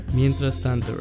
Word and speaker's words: Mientras tanto: Am I saying it right? – Mientras tanto, right? – There we go, there we go.